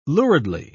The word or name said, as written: luridly